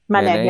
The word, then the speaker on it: Manet: And then we learned so much about Impressionists when we were there, like Manet, is that how you say his name? – Mm-hmm. Manet, yeah.